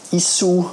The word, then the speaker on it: Issuu: Außerdem hat sie eine kleine Software empfohlen, die heißt Issue, I-S-S-U-U. Issue oder eben ausgesprochen Issue, das erlaubt auf einer digitalen Plattform äh, schöne Broschüren herzustellen, respektive elektronische Broschüren herzustellen.